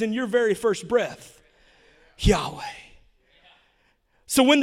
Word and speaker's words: Yahweh: In the Old Testament, the people of Israel called God Yahweh. And you spell that Y-H-W-H. And when it's written out, there's no vowels.